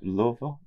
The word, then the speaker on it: Louisville: The proper pronunciation of Louisville? – Louisville.